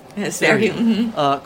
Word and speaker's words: Serhiy: We're going to end with a poem from Serhii, is that the right way to say it? – Serhii.